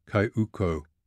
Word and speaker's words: cayuco: The coast along here is alive with hundreds of dugout canoes, or cayucas, as the Hondurans call them. They pronounce it cayuco.